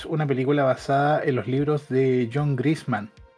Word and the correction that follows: Grisham: No, Grisan. Grisan, Grisham, Grisham. perdón. John Grisham. Creo que sería la pronunciación lo más correcta posible que es un escritor eh,